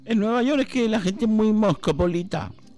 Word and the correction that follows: cosmopolita: ¿Se dice así? – Sí. Más o menos. Cosmopolita, querrá usted.